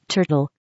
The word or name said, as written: turtle